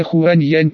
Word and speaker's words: Хуань Янь: Имя Хуань Янь произносится в китайском так же, как и огненный феникс, только наоборот.